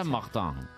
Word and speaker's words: Martin: Si es francesa, es Martin.